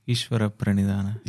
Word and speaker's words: Ishvara Pranidhana: Ishvara Pranidhana. – Yeah, that one. Ishvara Pradhyanama. – Ishvara. – Ishvara. – Prani. – Prani. – Dana. – Dana.